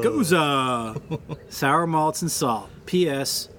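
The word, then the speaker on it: Gose: It's apparently, according to the pronunciation stressed in his comment and response, Goza.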